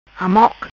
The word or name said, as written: Amoque